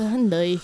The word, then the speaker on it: Hyundai: Is it Hyundai or Hyundai? – It's Hyundai. – I'm just being a douche. – Oh, okay, whatever. A four-door Hyundai. – It's a Hyundai.